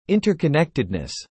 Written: interconnectedness

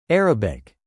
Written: Arabic

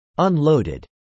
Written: unloaded

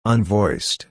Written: unvoiced

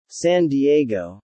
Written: San Diego

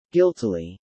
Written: guiltily

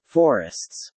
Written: forests

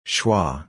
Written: schwa

